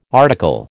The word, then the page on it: article: American English